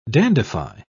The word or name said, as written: dandify